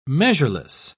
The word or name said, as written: measureless